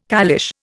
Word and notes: caliche: Indian

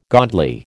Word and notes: godly: American English

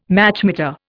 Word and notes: Machmeter: Indian

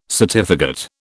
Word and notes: certificate: British English

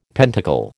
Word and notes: pentacle: American English

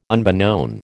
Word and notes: unbeknown: American English

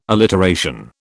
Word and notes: alliteration: British English

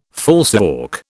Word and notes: falsework: British English

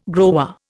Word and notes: grower: Indian

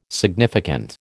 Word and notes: significant: American English

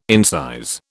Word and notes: incise: British English

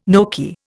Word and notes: gnocchi: Indian

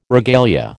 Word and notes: regalia: American English

